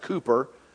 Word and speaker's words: Cowper: The story of William Cowper, or some would even suggest that it's, although it's spelt C-O-W-P-E-R, it may be pronounced Cooper.